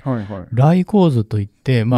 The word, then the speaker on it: 来迎: 迎えに来ると書いて来光と読むんですけど、